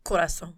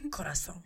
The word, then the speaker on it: Corazón: how do you say it? – Corazón.